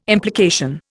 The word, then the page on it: implication: Indian